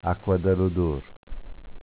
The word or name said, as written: Acqua da l'odor